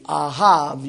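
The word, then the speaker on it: ahav (אהב): A-H-A-B, ahav, you pronounce the B like a V in the Hebrew.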